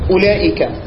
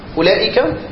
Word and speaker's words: أولئك: أولئك وأولاء وأولى الواو، انت لم تنطق الواو ولا كلمة من دول، انت ضميت بس.